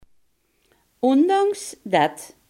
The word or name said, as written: desondanks